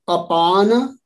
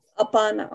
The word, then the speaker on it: apana: So the pronunciation is prana, apana, samana, udana, vyana. Not apana, apana. – Apana, okay.